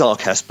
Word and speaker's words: Arcas: Did I say that right? – We'd like to think of it as Arcas, but Arcas.